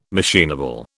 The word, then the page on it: machinable: British English